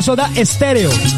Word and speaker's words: Soda Stereo: No con E, sino que así se pronuncia, Soda Stereo. Pero es con S, Soda Stereo.